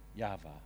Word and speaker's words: Yahweh: Well, it's actually Yahweh, is how you say it.